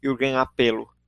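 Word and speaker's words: Jürgen Appelo: E estou duas vezes o Jürgen Apelo, para quem quiser procurar por ele, o nome dele escreve com J, é Jürgen Apelo com dois P's, né? E a pronúncia é Jürgen Apelo.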